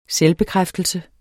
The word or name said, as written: selvbekræftelse